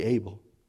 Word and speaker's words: Abel: I'm glad my mom named me Abel. Because every challenge that I've had, I said, your name's Abel, dude. I spelled A-B-L-E, it's spelled the right way, A-B-E-L.